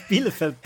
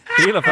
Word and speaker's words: Bielefeld: Can you never pronounce Bielefeld properly? – Bielefeld.